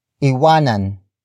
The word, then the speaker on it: iwanan: Iwanan ang inggit. Iwanan ang inggit. Iwanan ang inggit. It means leave envy behind. The focus is on envy. Word for word it's iwanan ang Inggit.